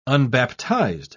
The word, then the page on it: unbaptized: British English